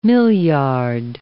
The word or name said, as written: milliard